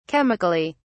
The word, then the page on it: chemically: British English